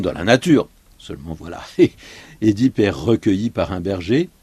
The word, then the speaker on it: Œdipe: Alors, il faut dire Édipe, hein. La plupart du temps, les gens disent Œdipe. En principe, il faut prononcer Édipe.